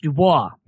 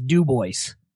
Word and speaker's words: Dubois: So in Wyoming, there's a town, and I shit you not, it's spelled D-U-B-O-I-S. In French, that would be pronounced Dubois. – Yeah. – Okay. In Wyoming, and I kid you not, the name of the town is Dubois. And if you call it Dubois, you offend people.